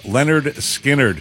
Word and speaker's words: Lynyrd Skynyrd: And also, it was 53 years ago today, back in 1973, that Lynyrd Skynyrd released their self-titled debut album, pronounced Lynyrd Skynyrd, featuring classics like Gimme Three Steps, Free Bird, and the tune we'll kick off the lunch with right after this.